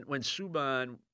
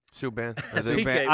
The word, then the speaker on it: Subban: You know, when Subban went, what are we pronouncing him anyway? – Subban. – I've called him everything. – Yeah, when PK. – I can call him a lot more. – I suppose it's Subban.